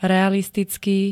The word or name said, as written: realistický